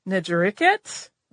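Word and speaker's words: Netjerikhet: So today's focus, Djoser, for example, had the Horus name of Njeriket. I've also consulted people and heard it pronounced Njeriket. Very subtle, but different.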